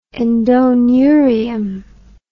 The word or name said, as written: endoneurium